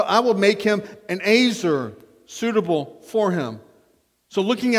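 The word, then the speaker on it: ezer: That Hebrew word is pronounced azer. Azer.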